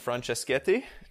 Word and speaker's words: Franceschetti: So we are so excited to welcome in Matteo Franceschetti. Did I pronounce that okay? – Yeah, you nailed it.